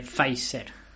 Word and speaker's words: Pfizer: Eh, ¿y, cómo pronunciar Peficer? Porque yo lo estaba pronunciando Peficer. Parece que no se pronuncia teaser. Y mira tú, eso que me. Yo por mí ya compensaste. Podemos dejarlo aquí si queréis. A ver si la vamos a cagar. ¿Cómo se pronunciaba, perdón, hijo mío? – Eh, Pfizer. Pfizer. Pues Pfizer, sí, creo pues que es Pfizer.